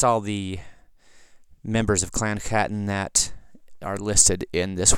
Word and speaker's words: Chattan: Starting off with actual Chattan or Catan, it's spelled with a CH in Gaelic, that's always a guttural sound.